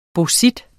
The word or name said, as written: bauxit